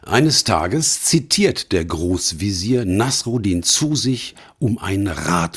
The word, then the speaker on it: Nasreddin: Der Hauptdarsteller dieser Geschichte heißt Nasruddin. Meine türkischen und arabischen Freunde sprechen den Namen allerdings anders aus. Nasreddin, Nasruddin Hoca oder ähnlich.